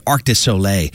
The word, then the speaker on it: Arc de Soleil: We heard Spoon in there too with Do Ya, Arc de Soleil. That's how you say it. Arc de Soleil with Riders on the Moon.